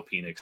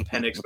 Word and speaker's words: Penix: Everywhere else, I just don't think – I know I said I love Michael Penix. It's my favorite pronunciation. I know it's Penix, but I don't care.